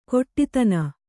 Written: ಕೊಟ್ಟಿತನ

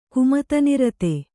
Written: ಕುಮತನಿರತ

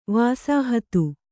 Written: ವಸಾಹತ

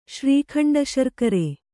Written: ಶ್ರೀಖಂಡಶರ್ಕರೆ

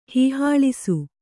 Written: ಹೀಹಾಳಿಸು

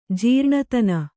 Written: ಜೀರ್ಣತನ

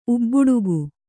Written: ಉಬ್ಬಡಿಗ

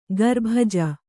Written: ಖರಬುಜ

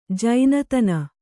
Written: ಜಾಣ್ತನ

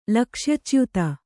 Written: ಲಕ್ಷ್ಯಚ್ಯುತ

